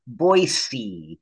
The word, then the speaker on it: Boise: And just in case you have to pronounce the word, it's Boise. We're not noisy for Boise.